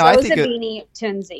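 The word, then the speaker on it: Zozibini Tunzi: No, Zozobini Tunzi.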